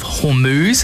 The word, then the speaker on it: Hormuz: Well, there's actually a backlog of that oil in the Strait of Hormuz, as I've heard it pronounced.